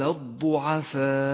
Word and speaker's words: الضعفاء: والآن اقرأ كلمة الضعفاء ولاحظ كيف رسمت، فقد زيد فيها واو رمادية وهي من زيادات الرسم العثماني، لا تلفظ هذه الواو، وكذلك اللام الشمسية التي بأول الكلمة تكتب ولا تلفظ. فقال الضعفاء.